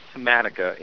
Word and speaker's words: Thematica: And you said it correctly. It's Thematica, and Thematica is actually Italian for theme, so it really resonates well.